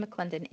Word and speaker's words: McClendon: So you can find me on social media, but if you really do want to just connect, my name is spelled T-A-I. My middle name is Ann, A-N-N, last name McClendon, M-C-C-L-E-N-D-O-N.